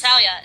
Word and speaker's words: Hetalia: I actually watched a few episodes of the anime that the specific anime we're going to be talking about, which is it pronounced Hetalia? – Yeah, yes. Hetalia.